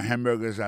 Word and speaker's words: hamburger: On a remarqué que dans le mot hamburger, ça a comme d'habitude, il y a trois syllabes là-dedans. Quand on appuie et puis plus ça, c'est sous premier syllabe là.